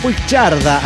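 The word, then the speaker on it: Puigcerdà: Un saludo desde Puigcerda, así se pronuncia, España.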